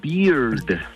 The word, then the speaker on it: beard: Porque barba en inglés se pronuncia beard, termina con D al final.